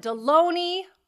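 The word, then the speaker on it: Delony: I am sitting here with the one and only Dr. John Deloney, rhymes with baloney.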